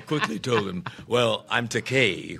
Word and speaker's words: Takei: He corrected the pronunciation of his name, but he then called me George Takei, a common mispronunciation of my surname because of the EI. They want to give it that Germanic Einstein, you know, pronunciation, mm-hmm. but it's the vowels in Japanese are pronounced like the Mediterranean languages, Italian, Spanish. It's Takei, E-I. However, there is a Japanese word pronounced Takai with an A, T-A-K-A-I, and that translates into English as expensive.